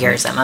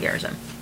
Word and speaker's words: Gerizim: The covenant renewal, Mount Gerizim and Mount Ebal. – Mount Gerizim. I've been saying it wrong. Gilgal. Mount Gerizim.